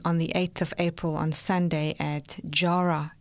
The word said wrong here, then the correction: Yarra: Is that Jarra? Jarra Valley. – Spelled with a Y or a J? – A Y. – Okay, Yarra.